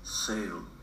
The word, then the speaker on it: sale: Então, a gente vê muito a palavra sale, cuja pronúncia é sale.